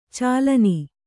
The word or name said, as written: ಚಲ್ಲೆಣ್ಣೆ